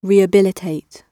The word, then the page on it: rehabilitate: British English